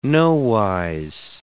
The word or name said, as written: nowise